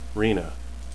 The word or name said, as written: renna